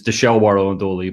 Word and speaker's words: Dishel, Bartle, and Dooley: So so for folks who aren't familiar with Dishel, Bartle, and Dooley, if I'm saying that correctly, please describe who you are. Thank you. – You were close. It's Dishel, Bartle, and Dooley, but it's a law firm based out of Lansdale originally.